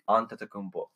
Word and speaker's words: Antetokounmpo: I think Giannis Antetokounmpo. – How did you even say that right? – Antetokounmpo.